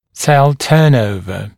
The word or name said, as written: cell turnover